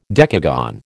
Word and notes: decagon: American English

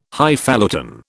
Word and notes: Highfalutin: British English

